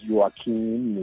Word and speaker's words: Joaquín: los, educaron en una comuna muy popular, por el Joaquín Phoenix, el nombre de Joaquín es, inclusive él pide que se pronuncie como se pronuncia en español, ¿no? No es Joaquín, ni Julio no, no, no, es Joaquín Phoenix,